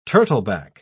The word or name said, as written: turtleback